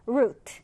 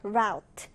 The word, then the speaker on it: route: 이 단어는 발음이 두 가지가 가능합니다. route, route도 되고 또는 route, route도 되는데요.